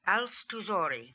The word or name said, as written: Alstoozori